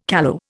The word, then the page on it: callow: Indian